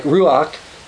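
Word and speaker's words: ruach (רוח): Now, when we talk about the Holy Spirit, I should point out that the Hebrew word for spirit, which we would pronounce somewhere like ruach, is feminine.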